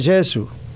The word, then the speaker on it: Gesù: En italiano se dice Gesù, ¿ve?